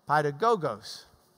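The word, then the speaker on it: Paidagogos (παιδαγωγός): Paidagogos.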